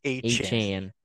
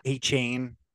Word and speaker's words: Achane: Achane. – Okay. – Not Achane. Achane.